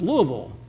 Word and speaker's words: Louisville: Is it, or capital of Kentucky, is it Louisville or Louisville? And she goes, it's Louisville.